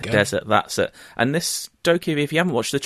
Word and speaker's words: Dokkaebi: I think it's pronounced Dokkaebi. It's Dokkaebi.